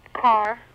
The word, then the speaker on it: Karr: K-A-R-R. – Like an automobile? – Yeah. – Yeah. I don't have it. – Sir, it isn't exactly like an automobile. No, it's K-A-R-R.